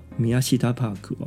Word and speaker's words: Miyashita Park: 它是位于这个楼层的二三楼哦，名称叫做雷亚斗，当然是日文发音哦，雷亚斗米亚西达帕克哦。